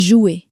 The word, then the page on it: jouer: french